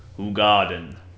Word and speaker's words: Hoegaarden: But no, I think somewhere they used to have it on the box where it shows you how to pronounce it. But it's Hoegaarden.